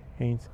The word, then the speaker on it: Hanes: Se escribe H-A-N-E-S. Heinz.